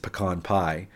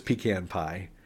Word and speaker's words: pecan: I learned that it's most likely an actor's choice is that Bridget Fonda mispronounces pecan pie because she orders pecan pie, which no... pecan pie. It's pecan pie. Pecan pie is said by rubes and idiots.